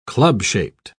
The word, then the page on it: club-shaped: British English